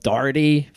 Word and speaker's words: Doherty: Spelled Doherty. For some reason, it's pronounced Doherty. I never really understood that. I think it might be an Irish thing, but a defender who brings it up the field.